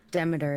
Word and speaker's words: Demeter: Demeter is the proper...